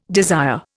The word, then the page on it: desire: Indian